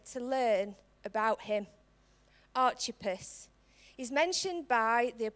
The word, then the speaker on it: Archippus: His name is pronounced two ways. It could be Archippus, or it can be Archippus. That's the way we spell it. That's the way I'm going to pronounce it. The reason being is, if anybody knows me, I love chips. And I thought, why not? Let's use the Archippus.